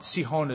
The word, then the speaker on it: Sihon: Then Israel sent messengers to this character by the name of Sihon or Sihon. Sihon is the right pronunciation.